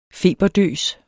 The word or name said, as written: feberdøs